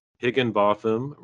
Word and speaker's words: Higginbotham: Well, hey, I want everyone to know I've been talking with Stephen Higginbotham, rhymes with Gotham, Craig Wilkins, and Aaron Rodgers of The Wheelworkers.